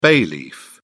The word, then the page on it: bay leaf: British English